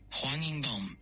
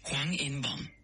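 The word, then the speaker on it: Hwang In-bom (황인범): Hwang In-bom en coreano se pronuncia de la siguiente manera. Transcripción fonética en coreano es Hwang In-bom.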